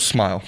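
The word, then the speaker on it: Smael: Smell, right? – Is it? – Let's look here. It is rhymes with smile. So, yeah, Smile Monday.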